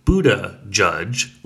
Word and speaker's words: Buttigieg: It's pronounced Buddha judge, like the Buddha, and then like a Supreme Court judge.